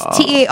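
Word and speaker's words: taarof: So then, well, did I say it right? – You say it. – Tarof. – Yeah, it's Tarof.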